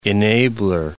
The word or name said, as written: enabler